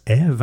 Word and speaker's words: 3V3: 3V3, qui se prononce Eve, hein, le 3 qui, quand on l'inverse, fait la lettre E, qui est composé uniquement de femmes et de sorcières non-binaires.